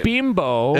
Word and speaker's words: Bimbo: Story, now it's bimbo.